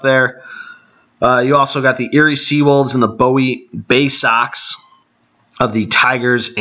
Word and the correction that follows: Bowie: Or Bowie, excuse me. Bowie, Maryland. It's spelled Bowie, like David Bowie. And I recently learned it was pronounced Bowie, so there's your fun fact for random towns and how you pronounce them.